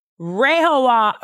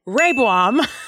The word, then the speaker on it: Rehoboam: Rehoboam, I said it wrong again. Rehoboam is Solomon's son.